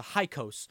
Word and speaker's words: Hyksos: it's pronounced the Hyksos, who were unknown origin.